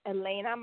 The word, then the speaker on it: Elena: My name is Elena. My name is spelled E-L-E-N-A.